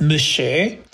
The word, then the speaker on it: Monsieur: One was called Monsieur, which they pronounced Mushu, and the other was called Sergeant, which was pronounced Sarjan.